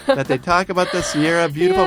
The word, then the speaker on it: Sierra: Right, and the pronunciation in English is called Sierra.